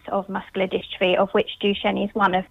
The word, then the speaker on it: Duchenne: So it's pronounced Duchenne. – So it's sort of a French thing going on, is it? – It is French.